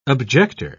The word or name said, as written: objector